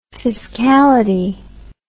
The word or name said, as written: fiscality